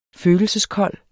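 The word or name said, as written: FØLELSESKOLD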